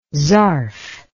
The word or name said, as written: zarf